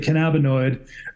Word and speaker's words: Kannabinõid: Uh, we I, uh, we pronounce it Kannabinõid, um, Kannabinõid since um, 2012, and so you were about 23.